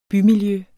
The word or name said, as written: bymiljø